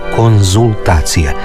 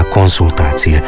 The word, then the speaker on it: konzultacije: A evo slučaja u kojem se često izgovara i piše s, a trebalo bi se reći z. I doktorandi i studenti prije obrane doktorata ili prije ispita idu svojim profesorima na konzultacije. Ne idu na konsultacije.